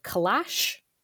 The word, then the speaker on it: calash: Um, can you explain for us what I don't even know if I'm pronouncing this correctly? Calash? Yep.